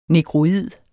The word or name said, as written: negroid